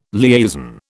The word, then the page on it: liaison: British English